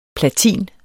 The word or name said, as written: platin